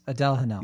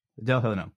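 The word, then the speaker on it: Adèle Haenel: And then that's basically where Adele Haenel, is that how you pronounce her name? – Yes, Adele Haenel. – Yeah, Adele Haenel.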